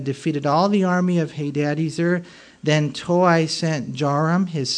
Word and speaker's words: Hadadezer: You're like, why is he saying Hadadezer? Because really, that's how you pronounce it. It's the E is long, Hadadezer, and brought them to Jerusalem.